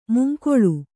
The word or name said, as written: ಮುಂಗಾಳು